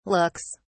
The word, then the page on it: looks: British English